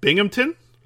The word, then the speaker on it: Binghamton: The important thing is the mayor of Binghamton, I said it right there, right? – Yeah, yeah.